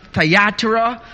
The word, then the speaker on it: Thyatira: And this church tonight is the church in Thyatira. Other speakers, other theologians will pronounce it Thyatira. So I may go back and forth to how it's pronounced, but you know where exactly we are at.